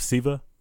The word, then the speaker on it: Sceva: Sons of Sceva? – Yes, yes.